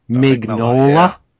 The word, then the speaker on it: Mignola: And, some guy, how do you say it? Mike Mignola. Yeah.